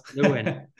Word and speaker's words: Lewin: Joseph Lewin. Yeah, close. Lewin.